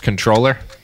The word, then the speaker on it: comptroller: Comptroller? – Because it's supposed to be pronounced controller. – not the way the Massachusetts comptroller pronounced it, which well, is kind of funny.